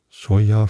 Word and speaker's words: Cholla: Und da kamen wir dann noch an einer Stelle vorbei, das hieß Chola Gardens oder so. Und Chola, also C-H-O-L-L-A, Choya vielleicht auch, wenn es Spanisch ausgesprochen wird.